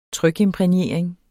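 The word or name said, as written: trykimprægnering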